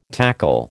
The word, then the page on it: tackle: American English